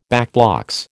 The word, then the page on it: backblocks: American English